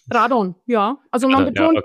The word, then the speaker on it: Radon: Die meisten betonen die erste Silbe. Manche sagen auch Radon oder Radon oder so. Also die Mehrzahl der Leute betonen eigentlich die erste Silbe. Aber es hat beides seine Richtigkeit.